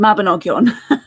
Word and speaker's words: Mabinogion: but the Mabinogi, is that how you say that? Mabinogian? – The Mabinogion. Well,